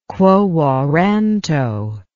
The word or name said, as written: quo warranto